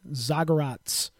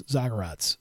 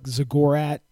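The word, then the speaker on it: Zagorac: And that is, by the way, how you pronounce it, Zagorats. I think that's the correct way. I've been watching some of his film, I guess you would say. That's how they say it, their commentators, Zagorats. So that's what I'm going with. It looks like Zagorak or Zagorach or Zagorach or something like that. Zagorats. Zagorats, like Divots, like everyone said Divots at VAC, Rade Zagorats.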